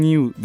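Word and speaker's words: Niue: Bu nasıl okunuyor şimdi e, bilmediğim için ben New diye okuyacağım. E, N-I-U-E olarak yazılıyor. Burası da Yalnız Cennet olarak adlandırılıyor.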